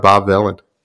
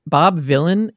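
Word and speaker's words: Bob Vylan: Also, it's worth noting, Bob Villain is spelled V-Y-L-A-N. So it's like very close to Bob Dylan.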